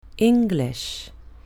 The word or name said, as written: english